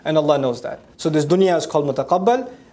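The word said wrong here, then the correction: مُتَقَلَّب: Or mutaqallab, sorry. I keep mispronouncing it. Mutaqallab.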